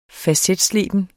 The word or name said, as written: facetsleben